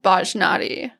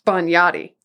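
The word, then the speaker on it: Bajnotti: And just, like, right quick, I want to settle the pronunciation of a word. I said Bajnadi. – Bagnati. – And who's right?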